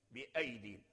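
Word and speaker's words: بأييد: مثال الحرف الموجود في المصحف ولكن لا يلفظ بها، قول تعالى، أمثلة كثيرة، قوله تعالى والسماء بنيناها بأيدين، بأيدين رسمت في المصحف بياء أين، بأيدين في المصحف، إلى شفت المصحف مرسومة بياء أين، هل ننطق بياء أين؟ لا، بياء واحدة ساكنة، بياء واحدة ساكنة، بأيدين والسماء بنيناها بأيدين، بأيدين.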